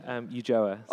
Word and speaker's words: Ulloa: Ulloa.